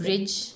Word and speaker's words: Brij: Can I try to pronounce bridge? That? Bridge. Bhasha. Like the bridge. Yeah, like bridge, bridge bahasa.